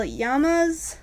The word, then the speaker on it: Yanma: They are called Royal Yamas. I think that's how you pronounce it. – Yeah. – Y-A-N-M-A. It's a very short word. – Yamas.